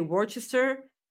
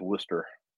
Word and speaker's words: Worcester: I have no idea how that city is pronounced, but I will always say Worcester. – That's what I used to call it myself, but... – Yeah, but like, that's not how you pronounce it, right? – No, nope. I learned that from Jim Ross, that it's Worcester.